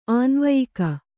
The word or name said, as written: ಆನ್ವಯಿಕ